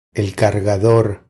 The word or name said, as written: El cargador